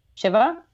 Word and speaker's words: Shiva: isn't disrespectful in the context of a Shiva, which is pronounced differently in my family, but like Shiva is kind of how they say it.